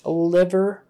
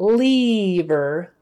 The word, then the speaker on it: liver: Say someone is having liver problems, say liver, not lever, liver, lever, liver, lever, liver.